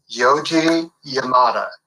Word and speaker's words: Yoji Yamada: Yoji Yamada.